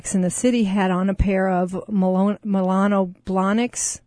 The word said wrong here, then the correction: Manolo: Manolo Blahniks.